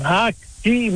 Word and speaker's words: Haki: Haki, moet ik zeggen, zo moet ik het uitspreken, uit Haaksbergen.